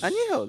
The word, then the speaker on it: Añejo: Añejo, that's how you say it.